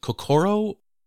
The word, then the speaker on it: kokoro: Is it kokoro? – Perfect. Nope, you said it perfectly.